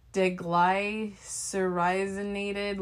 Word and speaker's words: deglycyrrhizinated: So it's called deglycyrrhizinated licorice. I'll spell it for you. DEGLYCYRRHIZINATED.